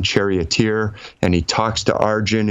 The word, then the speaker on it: Arjuna: The story of the Bhagavad Gita, where Arjun, is spelled Arjuna, but I think they usually pronounce it Arjun, is talking with Krishna.